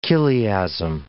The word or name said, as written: chiliasm